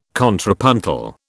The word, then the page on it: contrapuntal: British English